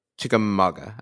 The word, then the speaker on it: Chickamauga: Yeah, and he had a possible sighting of green eyes from the Chickamauga, I think, according to the YouTube video I just watched to learn how to pronounce it.